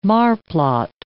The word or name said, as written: marplot